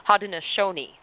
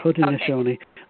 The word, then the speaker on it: Haudenosaunee: and I'm see if I can pronounce this correctly, Haudenosaunee. Is that correct? – That's pretty good. That's good. Okay, yes. – Okay. – Haudenosaunee.